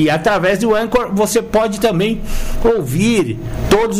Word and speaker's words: Anchor: Anchor. Se escreve com CH.